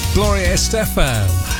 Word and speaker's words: Estefan: Gloria Estefan and Anything For You, and that is how you pronounce her name.